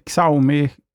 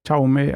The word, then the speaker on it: Xiaomi: Eh, det finns ju många andra tillverkare som, eh, som vad är de, de här kinesiska Xiaomi, eller hur uttalas det? Xiaomi, Xiaomi, ja något åt det hållet är det i alla fall. Jag tycker du är nära nog.